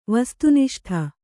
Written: ವಸ್ತುನಿಷ್ಠ

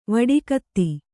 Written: ವಡಿಕತ್ತಿ

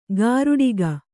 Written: ಗಾರುಡಿಗ

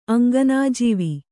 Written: ಅಂಗನಾಜೀವಿ

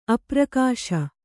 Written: ಅಪ್ರಕಾಶ

